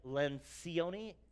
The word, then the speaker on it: Lencioni: It's phonetically like Lencioni, L-E-N-C-I-O-N-I.